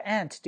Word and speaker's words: aunt: My aunt Jennifer is an actress。这个 aunt 这个 t 为什么不发音呢？你记一个小的发音原则：t 后面加一个辅音，t 后面加一个辅音，这个 t 呢？都可以省略掉，或者是当 t 这个辅音字母在最后的时候，在一个单词最后的时候，它直接省略。